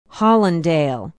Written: hollandale

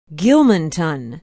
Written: gilmanton

